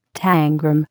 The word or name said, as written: tangram